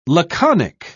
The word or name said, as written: laconic